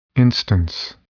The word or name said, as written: instance